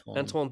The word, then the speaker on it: Antoine: Antoine Dupont.